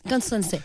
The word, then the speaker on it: Constanze: Constanze, yes.